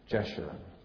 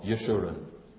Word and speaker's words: Jeshurun: Here it is plain generous blessing, as he calls God's wayward people Jeshurun, or Jeshurun. – or Yeshurun. We could get more and more Hebrew with the pronunciation, but you can see it there.